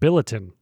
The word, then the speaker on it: Billiton: Like bulletin with an I? – Pretty close, yeah. It actually kind of looks like Britain, but Billiton, kind of how it's spelled.